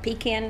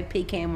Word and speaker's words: pecan: They're like pecan notes. To me, we say pecan where I'm from. – Pecan is right. It's the right way to say it.